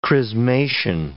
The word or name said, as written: chrismation